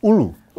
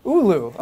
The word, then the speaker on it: Oulu: Pronounce the name of the place in Finland for me, please. – Oulu. – Oulu, okay. – Yes.